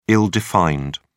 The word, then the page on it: ill-defined: British English